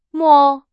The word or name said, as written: 摸